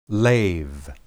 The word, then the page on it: leb: hebrew